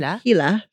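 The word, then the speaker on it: Heeler: Healer. But you have to say it like an Australian. – Hila.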